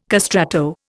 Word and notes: castrato: Indian

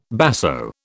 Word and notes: basso: British English

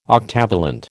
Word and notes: octavalent: American English

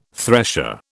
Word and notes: thresher: British English

